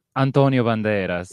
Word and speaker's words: Antonio Banderas: Can you say Antonio Banderas? I feel like you can say his name better than I can. – Antonio Banderas. – Yes, yes.